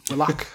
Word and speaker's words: Willock: Sorry, how did you say that the first time? – Willock. – Yep. – Yep. – That's how it's always being said.